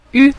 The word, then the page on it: yu: yu5